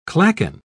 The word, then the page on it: clachan: British English